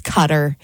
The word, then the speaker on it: Qatar: And then also on here was Qatar, which I say wrong as well. It's Cutter.